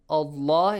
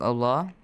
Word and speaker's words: Allah: So the rule says that if you have fatha or kasra or dhamma be right before the word Allah must be pronounced with a heavy sound.